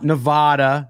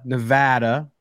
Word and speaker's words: Nevada: Not Nevada, Nevada. For those of you who are saying it wrong, you're welcome.